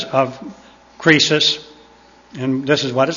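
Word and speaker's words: krisis (κρίσις): The Greek word is pronounced krisis, spelled K-R-I-S-I-S, as in crisis in the English language, except the C is K.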